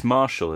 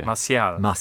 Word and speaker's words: Martial: It's Marshall as well, isn't it? – No, it's not Marshall. No? – Anthony Marshall? – No. – Okay. – Martial.